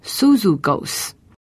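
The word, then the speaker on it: Suzugos (Σύζυγος): 在这一段经文当中有一个很有意思的一句话，是提到这真实与我同父一轭的，这个同父一轭的这个字，它很可能是一个人的名字，这个人的名字的发音呢叫做 Suzugos，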